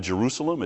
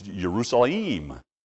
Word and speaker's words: Yerushalayim: So it's not Isaac, it's Yitzhak. It's not Jerusalem, it's Yerushalayim.